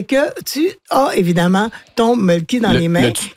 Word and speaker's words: Mölkky: On prononce Mölkky. – Ouais. – Et c'est M-O-L-K-K-Y.